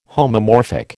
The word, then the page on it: homomorphic: American English